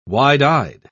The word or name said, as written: wide-eyed